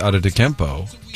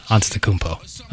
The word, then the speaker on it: Antetokounmpo: And you got props yesterday for saying Giannis Antetokounmpo during your updates. – Yes, Antetokounmpo. – Okay. – I got praise for pronouncing it correctly, and you just pronounced it incorrectly.